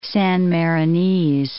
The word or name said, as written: San Marinese